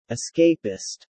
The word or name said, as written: escapist